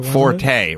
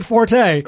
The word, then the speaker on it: Forte: And Penn kept saying, they're not pronouncing it right. It's Fort. – Forte. – Well, my argument is, if everyone says Forte, then it's Forte. That's the way it is. – There's a car and a football player currently named this thing, and we pronounce it Forte.